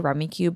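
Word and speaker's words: Rummikub: I say Rummy Cube, but I think it's pronounced Rummy Cub.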